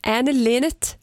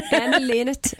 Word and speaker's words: Anne Linnet: Idag fyller nämligen Anne Linnet 73 år. Jag vet inte om man uttalar det så. – Gör man. – Anne Linnet 73 år.